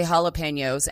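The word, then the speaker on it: jalapeño: Chuck's also the reason I say Jappalanos. I never say jalapenos. And when I say Jappalanos, I always get a look. And I'm like, I know it's pronounced jalapeno.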